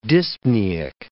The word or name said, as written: dyspneic